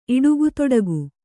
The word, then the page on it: ಇಡುಗುತೊಡಗು: iḍugutoḍagu